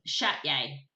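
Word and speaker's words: Csejte: it's Shatye, really. Shatye is really the pronunciation. Something like that anyway.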